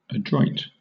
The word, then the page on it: adroit: British English